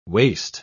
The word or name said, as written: waste